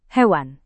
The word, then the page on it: hewan: Indonesian